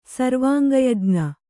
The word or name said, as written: ಸರ್ವಾಂಗಯಜ್ಞ